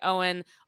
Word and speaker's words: Owyn: O-W-Y-N, pronounced like Owen, only what you need.